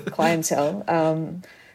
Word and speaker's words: Clientele: And I mean, obviously, and one thing that has made the Cleontel, here I am about to do the Australian pronunciation of Cleontel.